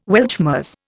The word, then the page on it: Weltschmerz: Indian